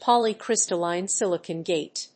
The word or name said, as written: poly‐crystalline silicon gate